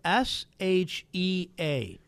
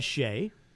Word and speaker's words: Shea: Her name is spelled S H E A. – Right. – I would have said Shay, right? – Yes. – Because that's how it's pronounced. – Yes. – But for her, it's Shea.